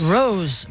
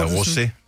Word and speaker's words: Rosé: Jeg tror, du udtaler navnet forkert. Det er derfor, at hun ikke har ringet tilbage til os. – Rose. – Nej, Rosé.